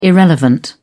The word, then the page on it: irrelevant: British English